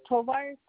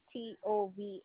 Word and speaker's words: Tovar: Can we try maybe my maiden name, Tovar, Tovar, because that was my email.